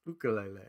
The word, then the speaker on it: ukulele: So in Hawaiian, every vowel is pronounced individually. So if you have a vowel, then a consonant vowel, and then another vowel, that's basically three syllables. So we would just run through and say ukulele. That would be ukulele. The correct way to pronounce it in Hawaiian. So it's ukulele.